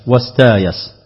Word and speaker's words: استايس: أي قرأ البزي بخلف عنه بالقلب والإبدال، أي جعل الهمز مكان الياء والياء مكان الهمز مع بقاء هيئة الكلمة ووزنها، ثم أبدل الهمزة ألفا فيصير النطق يايس واستايس.